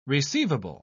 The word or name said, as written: receivable